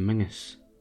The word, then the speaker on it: Menzies: But you get used to get shops in Scotland and Britain called John Menzies, but Menzies is pronounced Mingus.